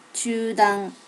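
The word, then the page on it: chudan: native Japanese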